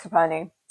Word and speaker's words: Coperni: So like my natural starting point is, I think this is how you say it, Coperni. – Yes, Coperni.